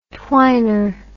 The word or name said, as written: twiner